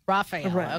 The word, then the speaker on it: Raphael: And then there's Raphael. My son is very particular because he got it from a name from a turtle. But which one does he insist upon? – Raphael.